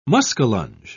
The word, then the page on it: muskellunge: British English